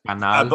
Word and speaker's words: Canal: It says it's on, is it pronounced Canel? Another, like a French channel? – Yeah, it sounds like the word channel in Spanish. – Yeah. – Canal.